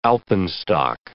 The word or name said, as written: alpenstock